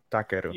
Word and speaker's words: Takeru: And I think you said it right. You said it more right than I did. Takeru. – You you, you as oh, so you broke up, but yeah. – no, it's Takeru.